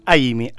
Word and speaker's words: Aimi: No, es Amy, A-I-M-I, con I latina.